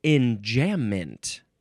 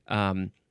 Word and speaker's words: enjambement: Oh, you can also spell it E-N-J-A-M-B-E. You could throw an E in there. Enjambment. Whoa, you can also pronounce this. This seems like more the proper way to say it. Enjambement? Enjambement. Yes, because it's probably French.